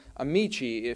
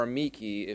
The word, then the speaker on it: amici: And the plural is amici if you're going for the classical Latin, or amici if you want to keep that late modern high hard C.